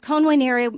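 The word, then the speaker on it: convenire: In Latin, you don't pronounce V as V. You pronounce it as a W. And so it's con venire means to come together.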